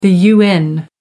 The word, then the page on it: un: American English